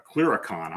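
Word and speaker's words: clurichaun: A chiricon, I'm sorry, that's how it's pronounced.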